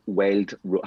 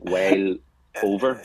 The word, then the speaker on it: Wild Rover: I have to say it like this, because when I said, most people don't understand me. Wild Rover. If I say it normally, Wild Rover, it sounds like Wild Over.